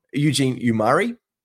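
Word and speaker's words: Eugene Omoruyi: Eugene Abaji and Omar. I see his name wrong. Eugene Umari.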